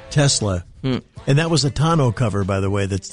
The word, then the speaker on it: tonneau: Yeah, I mean, from the guys who have one, I've always heard it pronounced tonneau cover. – It is tonneau, yeah.